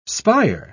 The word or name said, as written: spire